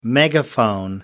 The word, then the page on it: megaphone: American English